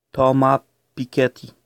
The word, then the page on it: Thomas Piketty: French economist